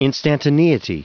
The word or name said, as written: instantaneity